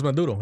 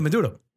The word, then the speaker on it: Maduro: Apparently they were invited by, how do you say the president's name, Maduro? – Nicolas Maduro. Yeah, yeah, Maduro. And all these activists took it over, and apparently they lost water and power, and there's protesters outside.